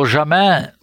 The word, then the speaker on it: Jamin: Jamin, yes.